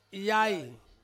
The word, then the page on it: yai: Ansus